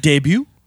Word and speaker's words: debut: So we're here to talk about your debut single. I think I pronounced that word wrong. Is it debut? – It's debut.